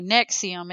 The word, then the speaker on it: NXIVM: Anyway, Nexium, it's spelled NXIVM, is a self-described American multi-level marketing company.